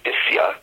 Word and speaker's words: Basya: Basya, a common name today, should be Bisya, as the daughter of Paro is called in Divrei HaYamin.